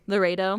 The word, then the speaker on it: Laredo: Laredo.